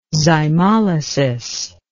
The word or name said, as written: zymolysis